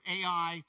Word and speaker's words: Ai: And they're having a party, and they're celebrating, and they kind of look over, and the next town, and you can either pronounce it A or AI, is over there.